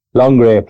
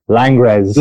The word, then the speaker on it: Langres: You're pronouncing Longres probably as it should be, very French and correctly. If you were driving and talking earlier, you call that Langres.